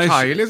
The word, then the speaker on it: Kylie: Och man säger inte Kylie eller Kylie, utan Kylie säger man.